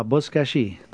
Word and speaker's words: Buzkashi: But your key to winning is to enter in the subject line the word Buzkashi, or how do you say Buzkashi? – Yeah, Buzkashi.